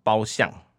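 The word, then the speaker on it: 褒珦: 那有一个他的大臣叫做褒相、就是这个方向的相，这个念的读音是这样子。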